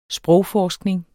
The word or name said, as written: sprogforskning